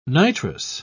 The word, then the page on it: nitrous: British English